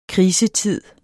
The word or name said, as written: krisetid